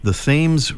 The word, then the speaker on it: Thames: Ledyard, Connecticut, and that's, if I'm not mistaken, right on the Thames River. Is that right? – Yes. – Okay, and that's not pronounced Thames the way it is in England? – No.